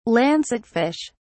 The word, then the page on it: lancetfish: British English